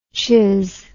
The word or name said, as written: chiz